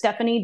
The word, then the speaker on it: Stefanie: Yeah, I'm on all the socials as Stefanie Directs, and my first name is spelled with an F instead of a PH.